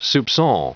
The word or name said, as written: soupcon